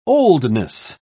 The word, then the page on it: oldness: British English